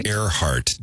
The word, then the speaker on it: Earhart: How do you say it? – Earhart Day. – Because that's how you say it. Air.